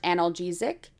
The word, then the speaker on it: analgesic: This was 85 controlled trials, so almost, you know, around 3,500 people, and they found that hypnosis produced a moderate to large analgesic. Am I saying that right? – Yeah.